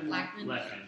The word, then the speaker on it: Lachman: Wonder Barry Bostwick and then, they're honoring Ed Lachman, is that how you pronounce him? – Lachman.